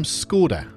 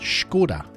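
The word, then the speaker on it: Škoda: We call them Skoda. Yeah? Did you know we're actually pronouncing it wrong? It's not Skoda at all, it's Skoda with a sh.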